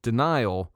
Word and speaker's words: Danile: Not you're in denial, but it's spelled D-A-N-I-L-E, Denial.